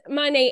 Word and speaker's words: Monat: But it is Monet,